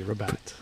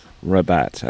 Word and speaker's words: Rabat: Hopefully I pronounced that right. You know, it's French, a little bit of... – It was pronounced perfectly, Rabat. – Rabat, okay.